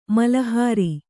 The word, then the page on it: ಮಲಹರ: mala hara